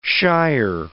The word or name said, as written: shire